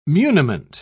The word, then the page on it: muniment: British English